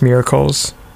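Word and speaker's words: Miarklez: It's like M-I-A-R-K-L-E-Z. I think it's supposed to be pronounced Miracles.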